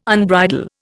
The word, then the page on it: unbridle: Indian